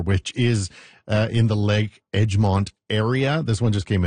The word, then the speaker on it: Egmont: So just Lake, and I hope I say it right, Egmont, E-G-M-O-N-T. I want to say Edgemont. – I say Edgemont. – Is it Edgemont? – Well, that's what I say. I could be wrong.